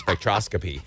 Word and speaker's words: spectroscopy: I know that you wanted me to read that so I could try to figure out how to pronounce spectroscopy.